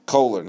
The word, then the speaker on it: Kohler: and you're facing Kaler, you're facing some of these players in a short series, Kohler, not Kaler, I keep mispronouncing it.